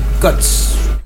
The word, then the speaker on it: Guts: O Guts sería la pronunciación, sí.